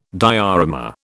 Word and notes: diorama: British English

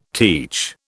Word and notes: teach: British English

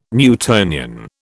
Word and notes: Newtonian: British English

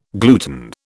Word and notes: gluten: British English

